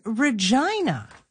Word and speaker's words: Regina: It is Regina. – That's a fair Regina observation. – Through Ryan. It threw all of us. – You could say Regina. You could. – You could, but you'd be mispronouncing it.